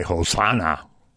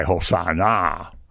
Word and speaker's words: Hosanna: Actually, they're going to cry Hosanna, which is the emphatic form. And in Hebrew, it means come and save us. Come and save us. And when you put that hard accent on the last syllable, it means come and save us now.